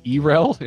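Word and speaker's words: Yrel: We had a big argument on Core whether you're supposed to pronounce her name Urel or not, but that's how Blizzard pronounces it, so F off, everybody. That's how they say it.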